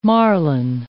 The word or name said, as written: marlin